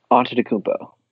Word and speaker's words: Antetokounmpo: I don't know how Orlando's going to be able to fight off Antetokounmpo. Did I say that right? – Antetokounmpo. You're close.